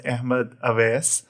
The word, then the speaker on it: Ahmad Awais: So, so my name is Ahmed Awais.